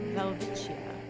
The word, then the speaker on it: Welwitschia: All right, well, I definitely pronounced that wrong. It's a Welwitschia.